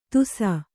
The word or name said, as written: ತುಸ